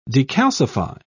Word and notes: decalcify: British English

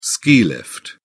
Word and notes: ski lift: British English

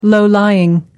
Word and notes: low-lying: American English

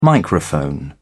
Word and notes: microphone: British English

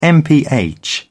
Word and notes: mph: British English

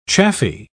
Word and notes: chaffy: British English

